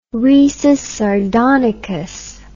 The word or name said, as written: risus sardonicus